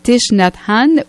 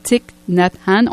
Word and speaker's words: Thich Nhat Hanh: C'est, je vous le rappelle, du maître bouddhiste vietnamien Thich Nhat Hanh ou encore Thich Nhat Hanh. On peut prononcer les deux.